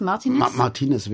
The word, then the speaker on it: Martinez: Martinez wäre die spanische Intonation oder Betonung.